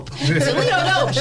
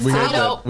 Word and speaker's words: Toledo: Toledo Dope. She called it Toledo Dope. You know, I told her, no, Mom, it's Toledo.